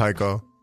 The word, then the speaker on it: Heiko: Nah, so Heiko comes from, so my last name is Jaco, mm-hmm. In Spanish is pronounced Heiko, and I just ran with it.